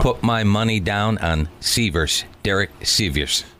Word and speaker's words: Sivers: It's spelled S-I-V-E-R-S. We're going to go with Sievers, right? – I believe that's the pronunciation.